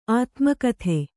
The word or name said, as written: ಆತ್ಮಕಥೆ